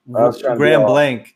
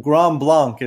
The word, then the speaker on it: Grand Blanc: Well, you said it right. Grand Blanc. Grand Blanc is the right pronunciation, but we're not classy enough in Michigan. We change everything to Grand Blanc.